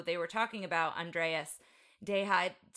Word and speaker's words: Deja: and it's Andreas Deja. It's not, it's not the Hispanic Deja, but he did Gaston.